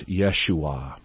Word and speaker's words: Yeshua: Well, some of our listeners may remember the Oswald Hoffman series, Yeshua. That's the Hebrew pronunciation of the name Jesus.